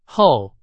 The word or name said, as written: 厚